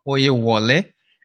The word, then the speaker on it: Oyewole: I am Dr. Oyewole.